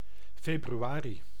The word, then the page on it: February: [ˈfɛb.juˌɛɹ.i]; /ˈfɛb.ɹʊ.ə.ɹi/; /ˈfɛb.ɹuˌɛɹ.i/